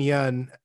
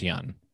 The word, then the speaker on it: Yeun: I think it's Yeun. – I think it's closer to Win.